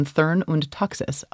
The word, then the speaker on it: Thurn und Taxis: Specifically, Samuel Alito is apparently now close personal friends with one Princess Gloria von Thurn und Taxis of Germany. Did I pronounce that properly, Melissa? – Yes.